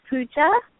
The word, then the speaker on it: Puja: My name is Pooja. – How you spell it? – K-U-J-A? – P-U-J-A. – Pooja, P-U-G-A. I heard a K sound, not a P sound.